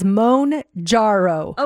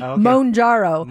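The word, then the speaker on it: Mounjaro: They have it phonetically here and it says Monjaro. – Okay. – Monjaro.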